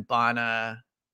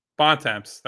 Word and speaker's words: Bontemps: Tim Bona. – Bontemps.